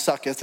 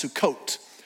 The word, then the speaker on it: Sukkot: I hope by now you've realized that that word is not pronounced sucketh. It's actually pronounced Sukkot.